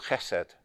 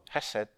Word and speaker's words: chesed: The problem with spelling it C-H-E-S-E-D is that some people pronounce the ch like cheese. Chesed doesn't work. But there is a K-H. It's a hard sound. It's in the back of the throat. Chesed. And to help our pronunciation of this, some people spell chesed K-H-E-S-E-D, or they spell it with a hard H at the front. Chesed. H-E-S-E-D.